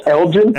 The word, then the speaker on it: Elgin: I said his name wrong, didn't I? – You said Michael England. You were talking. Oh, Freddy Krueger here. – how do you pronounce it? Elgin? – Mike Elgin. – Elgin. Yeah. Elgin. Okay. – Yeah, it could be Elgin, but so, I've heard every time I hear him, they always say Elgin.